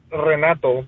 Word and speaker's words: Renato: Well, actually, my name is Renato, but I put it Nato. I get it butchered all the time. But you didn't think I could roll the R, huh? – Oh, I didn't know.